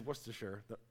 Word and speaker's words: Worcestershire: I think I got that right. Shoss. Google it. It's Worcestershire, according to the YouTube.